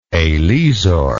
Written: eslisor

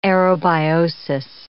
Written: aerobiosis

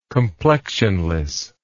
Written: complexionless

